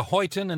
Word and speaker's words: Huyton: You grew up in, is it Huyton in Liverpool? – Am I pronouncing it? – Yeah.